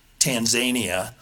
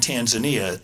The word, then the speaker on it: Tanzania: I had a teacher who pronounced the country Tanzania. – Oh, and I said, no, I think you mean Tanzania. There's Tasmania, you know, in Australia, but that country is Tanzania.